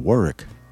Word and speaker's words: Warwick: When I was living down there, we lived in Tribe Road 13 in Warwick. It pronounced W-A-R-W-I-C-K, but if you said Warwick, everybody laughed at you.